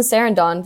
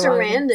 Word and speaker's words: Sarandon: Susan Sarandon. Did I say that correctly? I feel like I said it Sarandon. – Wrong. Sarandon.